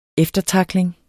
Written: eftertackling

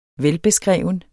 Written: velbeskreven

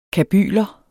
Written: kabyler

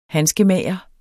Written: handskemager

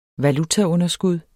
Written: valutaunderskud

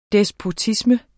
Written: despotisme

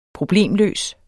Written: problemløs

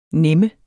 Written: nem

